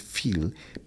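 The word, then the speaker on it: fils: Yo lo podría confundir con hijo porque, sí, profe, se dice fil. Pero resulta que al escribir, la pronunciación es fil, pero es con S para que yo diga hijo.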